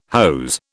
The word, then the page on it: hose: British English